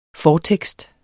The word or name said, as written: fortekst